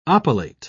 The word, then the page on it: oppilate: British English